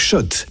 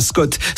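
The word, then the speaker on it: Schott: Jouer et gagner sur RMC avec Shot, la marque iconique new-yorkaise depuis 1913. Oui, Shot. Et pas Scott, ça fait des années qu'on prononce mal la marque.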